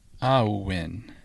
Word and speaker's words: Awen: So the term Awen, A-W-E-N, is a Welsh, Cornish, Breton word for inspiration. It is pronounced Awen. So if you picture A-H-O-O-H-W-E-N, Awen, but it's spelled A-W-E-N.